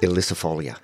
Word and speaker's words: ilicifolia: Elicifolia, yes. – Do you want to spell that for Liam? – I-L-I-C-I-F-O-L-I-A is the species, and hakea is H-A-K-E-A.